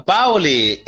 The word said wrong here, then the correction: Boale: Errou! Boale. – Opa, é Boale.